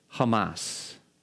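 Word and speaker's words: Hamas (חמס): The Hebrew word, it's actually pronounced in Hebrew, Hamas.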